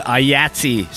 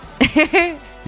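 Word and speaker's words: IATSE: So the Ayatsi strike vote. – Yes. – Right? – Is that how you say that? – I don't know. Yahtzee? Yahtzee. – Yes, I think that's how you say it.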